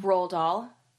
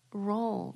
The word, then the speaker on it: Roald Dahl: Did I say his name wrong? – Roald Dahl. – Roll.